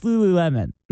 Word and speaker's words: Lululemon: Is that how you pronounce it? Lululemon? Yeah, it's Lululemon.